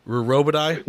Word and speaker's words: Robidas: Yeah, In Sorel, you fought, it might be the French pronunciation, Simone Robidas. – Robidas.